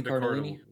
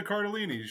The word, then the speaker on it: Cardellini: and then Linda, I'm going to pronounce this terribly. I'm pretty sure it's Linda Cardellini. Linda Cardellini.